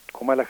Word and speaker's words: Kamala: Comala Harris, que es como se pronuncia, se acordaron una serie de cosas que sobre las cuales yo quisiera llamar la atención.